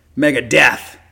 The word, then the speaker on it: Megadeth: You have to say it like that. It's a requirement. Megadeth.